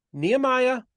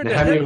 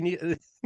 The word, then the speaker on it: Nehemia: Yeah, so Nehemia is the pronunciation I grew up with. I guess probably technically it's Nehemia, but I only had one person who ever called me that, professor at Hebrew University.